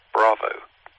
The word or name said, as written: bravo